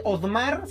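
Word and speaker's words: Oddmar: Bájense Odmar, se escribe O-D-D-M-A-R y está disponible para dispositivos Android.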